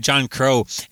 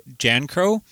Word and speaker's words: John Crow: So that song, John Crow, is actually another... The real pronunciation of that is Jan Crow.